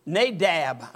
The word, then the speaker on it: Nadab: His name is Nabab. Nadab, I'll say it right.